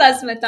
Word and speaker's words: Asmita: Oh my gosh, have we been mispronouncing your name the whole time? – No, it's Asmita. – Yeah, we have just been going Asmita. Oh no. – It is Asmita only. – Oh, okay. But is it supposed to be pronounced as Asmita? – In Bengali, yes. So I still hold on to my roots and I would call myself Asmita.